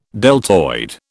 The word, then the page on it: deltoid: British English